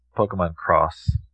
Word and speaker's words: Pokemon X: actually, this game's pronounced Pokemon Cross. – Yeah. – Actually. – Pokemon Cross. – A lot of people get it wrong.